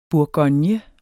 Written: bourgogne

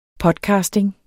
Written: podcasting

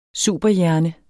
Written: superhjerne

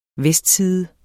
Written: vestside

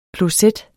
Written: kloset

